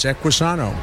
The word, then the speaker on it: Equisano: Well, the product that that you are here to talk about today, Equisano, am I saying it correctly? – Sano means health in Spanish. Equi for horse, Equisano.